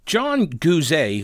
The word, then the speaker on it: Guzet: So, John Guzet, who I've just recently learned that's how he pronounces it, and I apologize for mispronouncing it for like apparently a decade,